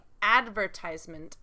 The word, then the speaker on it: advertisement: But yeah, in the advertisement, the stress is different, right? – Yeah. – First syllable in American English, advertisement.